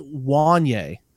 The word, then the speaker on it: Wanya: His name's actually pronounced Wanye, is how the first name is pronounced. – So not so tricky Wanya, one. – It looks like it'd be Wanya. It's Wanye. Yeah. Wanye Morris.